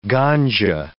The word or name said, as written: ganja